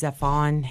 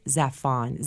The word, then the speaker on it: Zafón: I really love Carlos Ruiz Zafón.